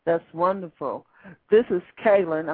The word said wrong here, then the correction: Kellen: No, it's actually Kellen. Like Kellen. – Kellen. Yes.